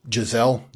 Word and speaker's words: Gesell: The district court judge, Giselle, G-E-S-E-L-L, that's how I will pronounce it,